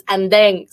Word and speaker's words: Andeng: So there's Andrea, but then there's also, like, in, you know, we say, some people say Andy. So it's a very loving way to say my name, but in the Philippines, it's Andeng. So it's the best.